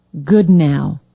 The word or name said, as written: goodnow